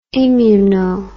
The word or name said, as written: immuno